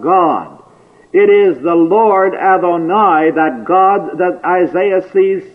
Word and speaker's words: Adonai: It is a different word which we may pronounce Adonai, and which literally means my masters.